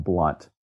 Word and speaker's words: Blount: It's Jeb, and the last name is pronounced Blunt. – Okay. – So it's like, spelled B-L-O-U-N-T, and like Emily Blunt, her real name is B-L-O-U-N-T, but she was smart and just took the O out so everybody knew how to pronounce it.